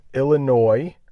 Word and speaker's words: Illinois: So state 21 is Illinois. Illinois. You may see that it has an S at the end. Don't pronounce the S. I'm not sure why there's an S there, but it's Illinois.